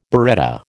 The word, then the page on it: biretta: American English